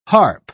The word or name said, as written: harp